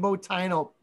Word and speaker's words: Boitano: Fucking look at that fucking Brian Boitano.